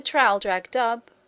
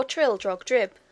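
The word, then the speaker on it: Traldragdubh: It contained a warrant for conducting me and my retinue to Trildragdub, or Trildrogdrib, for it is pronounced both ways as near as I can remember, by a party of 10 horse.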